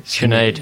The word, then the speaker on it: Sinead: Yeah, you know, you go through skill testing and Sinead McCain, say that right? – Sinead. – Sinead. You say that's it. That's a better pronunciation.